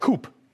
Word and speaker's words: coup: The guy was literally reading it off, and he read coup. The P is silent, but I digress.